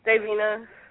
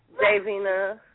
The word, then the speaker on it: Davina: Davina. – What did you do? – What's your name? – Davina. – Zazina, you said? – Davina, yes. – Okay. Say it. Spell it for me. – Spell it. D-A-V-I-N-A.